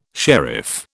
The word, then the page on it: sharif: British English